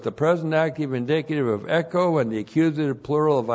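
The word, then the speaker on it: echo (ἔχω): Echo. E-C-H, long O, echo.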